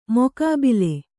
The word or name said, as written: ಮುಖಾಬಿಲೆ